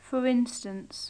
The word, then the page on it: for instance: English